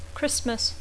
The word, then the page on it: Christmas: English